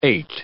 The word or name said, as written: ait